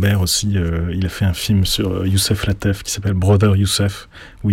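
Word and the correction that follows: Lateef: Latif. – Je veux toujours prononcer Latef sans savoir Latif.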